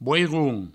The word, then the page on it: boiron: bouiroun